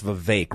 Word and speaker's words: Vivek: By the way, it's Vivek, rhymes with cake.